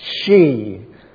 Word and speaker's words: Hsu: And by the way, his surname, just in case you're thinking of ordering the book, his surname is spelt H-S-U. He's Chinese. It's pronounced Shi, he explains in the book.